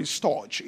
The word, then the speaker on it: storge: Now, the second is called, the pronouncing Greek, but let's call it storge. It's S-T-O-R-G-E.